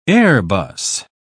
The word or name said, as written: airbus